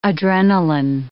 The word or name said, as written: Adrenalin